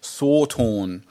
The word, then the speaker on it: satan: And the pronunciation is Sawtorn, which has become Satan in the way we anglicise things.